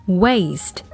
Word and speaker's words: waste: 那大家注意到呢，这个词是以 w 开头的，在读的时候呢，不要咬唇，一定呢要把嘴唇撅起来读成 waste。 waste，那么另外呢，这个 a 发的是双元音 a waste，小朋友们学会了吗？